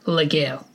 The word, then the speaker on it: Legere: Legere. That's French. I don't know any French. How it pronounces it? A silent G. That's just stupid. It's Legere.